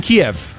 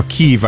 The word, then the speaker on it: Kyiv: But there is a dish which is called Chicken Kyiv. Or Kyiv, I suppose, if you pronounce it in the modern way.